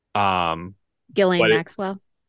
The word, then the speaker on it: Ghislaine Maxwell: Ghislaine Maxwell.